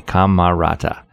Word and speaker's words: Camarata: I'll spell that T-U-T-T-I, as you might think. And then Camerata, C-A-M-A-R-A-T-A, Camerata, who helped co-found Disneyland Records, known today as Walt Disney Records.